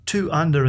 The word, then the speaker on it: 2UNDR: Well, look, essentially – Two Under, and the under is spelt UNDR, and it has a great logo.